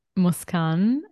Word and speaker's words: Moscan: You can find me at Emma Muskan. Very simple, just my name. It's okay. So it's spelled Emma, M-O-S-C-A-N.